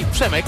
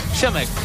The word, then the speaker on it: Przemek: My name is Przemek. Say Przemek. – Przemek. – Przemek. It's difficult to pronunciation, but yeah, sure.